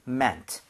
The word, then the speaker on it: meant: 자, 스펠링에다가 T만 뒤에다 붙여주시면 되는데 발음할 때 mean가 아니라 meant. meant 이런 식으로 발음이 됩니다.